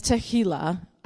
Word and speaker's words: tehillah: So, that word in the Hebrew, praise, is tehillah. Not tequila. Tehillah.